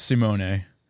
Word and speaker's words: Simone: I just want to say for the record, it is Simone.